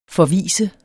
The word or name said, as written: forvise